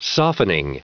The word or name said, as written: softening